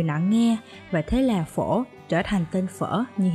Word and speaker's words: phở: Quay lại với cái tên phở, thì từ phở hiểu nôm là món ăn chế biến từ lúa gạo phổ biến trong đại chúng và phát âm là phổ.